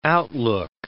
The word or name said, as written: outlook